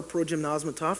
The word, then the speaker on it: progymnasmata: Moreover, curriculum existed in varying ways, and teachers were directly involved and not insignificantly elements of the Greco Roman progymnasmata, or progymnosmatov, that's how you say it, were nearly always present, even before the Greco Roman era.